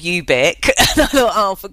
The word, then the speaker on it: ubique: And but I quite like the fact that ubique, pronounced like that, sounds a bit like soft and chewy in a way.